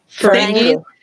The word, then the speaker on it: Ferengi: Ferengi.